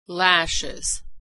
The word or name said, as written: lashes